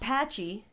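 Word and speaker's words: Patxi: But it's got patchy.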